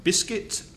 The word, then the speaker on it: biscuit: It's not biscuit, biscuit. It's just one vowel sound, and the vowel sound is I in the second syllable. So it's biscuit, biscuit. And the stress is on the first syllable, biscuit, biscuit.